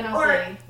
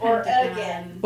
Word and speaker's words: Oregon: Oregon rhymes with gin. – Oregon. Yeah, it rhymes with gin. Gin. – Gin. – Yeah, oh, that's good. – Origin. – Oregon. She'll say origin. – Origin. – It's not gone. Oregon's not gone. It's just Oregon. – There's an O gun. At the end. – Gun. – It's like an Oregon, only there's a little extra syllable. Like or again.